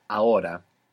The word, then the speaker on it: ahora: This is spelled A-H-O-R-A. A-H-O-R-A. Ahora. – Ahora. – Good. – Is the H silent in every Yeah, unless it's C-H, in which case it's like English, ch.